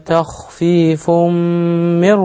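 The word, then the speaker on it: تخفيفٌ من: برضو معلش خد بالك هنا بقول ايه ذلك تخفيف هنا في عندنا نون التنوين هي تخفيف، النون التنوين اللي بتتنطق مش بتتكتب دي، جه بعدها الميم ادغمنا نون التنوين في الميم واخد بالك، وعلامة الادغام هنا إن الميم مشددة هي الحرف الثاني.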